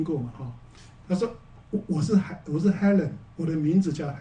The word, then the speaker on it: Helen: 我说只有法国人的发音会把这个 Helen 发音成 Elaine，好，是真的，这是真的，因为他们 H 是不发音的，好，所以 Elaine 是 Helen，法国人念到 Helen，他就会念 Elaine。